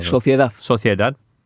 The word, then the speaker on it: Sociedad: Sociedad.